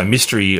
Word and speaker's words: Mistery: So Mystery is spelt with an I, so MISTERY.